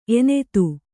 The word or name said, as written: ಎನತ್ತು